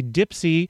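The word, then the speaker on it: DIYPSI: Some fun stuff coming up this weekend, the DIY Ipsy Indie Art Fair. See, I've already mispronounced it. It's actually Dipsy. It's spelled DIY with the I repeating itself once, but not really repeating as PSI.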